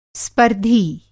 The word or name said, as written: स्पर्धी